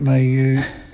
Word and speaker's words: Maunga: Is that how you pronounce it? Maunga. – Yeah, Maunga.